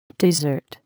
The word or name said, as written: dessert